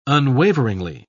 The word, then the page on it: unwaveringly: British English